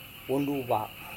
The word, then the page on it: wonduva: Serewen